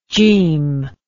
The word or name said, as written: Jim